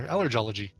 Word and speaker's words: allergology: Because you say allergy, so I guess you do a soft G there. Allergology.